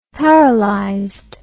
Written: paralyzed

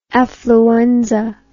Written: affluenza

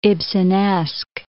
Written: Ibsenesque

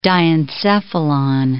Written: diencephalon

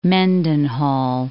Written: Mendenhall

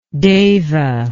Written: deva

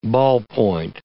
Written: ballpoint

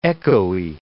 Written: echoey

